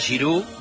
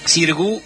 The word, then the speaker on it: Xirgu: 23 y 24 de mayo, doble show, Teatro Margarita Xirgu, o Xirgu, como te guste, o como se escribe, Margarita X-I-R-U, Xirgu, Teatro Margarita Xirgu, en San Telmo, doble show de Titanes en el ring.